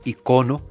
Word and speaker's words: icono: Esta palabra posee dos acentuaciones válidas. La primera es la grave icono con acento en la primera o,